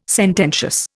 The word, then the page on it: sententious: Indian